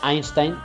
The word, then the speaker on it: Einstein: Y acabamos hoy con una frase de uno de los grandes, como siempre, Albert Einstein en este caso, o Einstein, para decirlo más exactamente la pronunciación real,